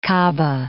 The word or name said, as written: Kaaba